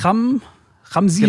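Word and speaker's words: Chamsin: Sag mal, wie entsteht da denn der, wie heißt es, Ramsin? – Genau, Betonung auf dem I.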